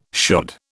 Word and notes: shod: British English

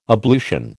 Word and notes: ablution: American English

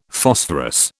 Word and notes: Phosphorus: British English

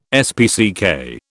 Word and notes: SPCK: British English

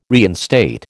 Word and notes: reinstate: American English

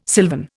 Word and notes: silvern: Indian

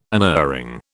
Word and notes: unerring: British English

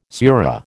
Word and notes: surah: American English

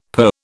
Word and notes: purr: British English

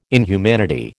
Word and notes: inhumanity: American English

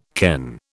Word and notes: ken: British English

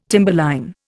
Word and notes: timberline: Indian

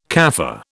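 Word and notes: café: British English

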